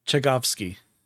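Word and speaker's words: Tchaikovsky: Chekazovsky. I probably butchered that horribly. – Chekovsky. – Yeah. That sounds better than the way I said it.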